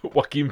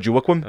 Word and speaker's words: Joaquin: Joaquin Phoenix. – Yeah, that's how you pronounce it. It's no Joaquin.